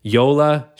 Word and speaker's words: Yola: It's spelled like Lola with a Y. – Yeah, Y-O-L-A.